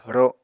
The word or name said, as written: ro